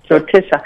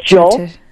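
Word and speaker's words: Jyotisha (ज्योतिष): Jyotisha. Jyotisha means light.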